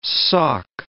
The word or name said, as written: Sauk